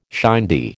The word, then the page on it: shindy: American English